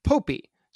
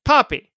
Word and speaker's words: Poppy: Yes, the name is pronounced Poppy, not Popey. So I think we introduced it as Poppy, but then we started to say Popey, but it is Poppy.